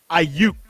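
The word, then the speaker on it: Aiyuk: Ayuk or the wide receiver? – Brennan Ayuk.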